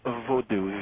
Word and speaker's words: Vodou: So venturing into voodoo, is that the right pronunciation? – Voodoo, V-O-D-O-U. – Voodoo. – Voodoo, V-A-D-O-U.